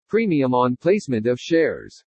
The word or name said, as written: PREMIUM ON PLACEMENT OF SHARES